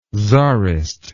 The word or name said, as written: tzarist